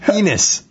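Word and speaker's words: Enes: And Anus Cantor, I think, might be the rookie of the year. – Did you just call him Anus? – How do you say it? – Enus.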